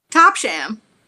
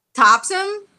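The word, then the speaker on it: Topsham: Let's hear how you would have pronounced it if you didn't know. – Top Sham. – Top Sham. And now, how do you remember how to say it from last week? – Topsom. – Topsom. Yep. There you go.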